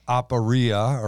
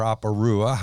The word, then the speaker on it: aporia: Perplexity in the Greek is aporia or aporua, however you want to say it. – According to the computer, you said it right.